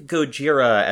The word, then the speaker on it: Gojira: If my band was named Godzilla and I learned about how it was actually pronounced in Japanese, I would not update the name of my band. – Uh, well, I believe Gojira as a name has a uh, has a relationship to whales.